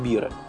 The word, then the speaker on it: бира: То же самое у них пишется МП, значит, эмпира написано, да, а читается как бира, пиво.